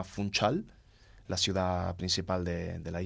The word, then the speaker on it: Funchal: Ahí se pronuncia Funchal.